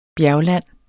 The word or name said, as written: bjergland